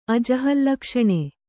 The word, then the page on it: ಅಜಹಲ್ಲಕ್ಷಣೆ: ajahallakṣaṇe